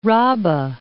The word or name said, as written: Raba